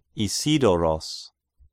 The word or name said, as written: Isidoros